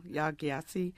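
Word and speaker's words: Yaa Gyasi: I'm thinking of Homegoing by, is it pronounced Ya Gyasi? – I think so, Ya Gyasi.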